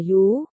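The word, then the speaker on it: yú (鱼): Liền mua ngay, chữ cá đồng âm chữ khỏi bệnh, yêu là cá, đọc là yú, yêu là khỏi bệnh, đọc là uy, cũng là điều tốt lành a, ngươi cắt cổ hắn hả?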